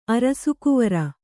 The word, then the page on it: ಅರಸುಕುವರಿ: arasukuvari